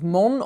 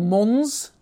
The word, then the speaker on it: Mons: So, the town of Mons, or Mons, depending how you like to pronounce it.